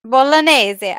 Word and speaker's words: Bolognese: How do you say that? – Bolognese.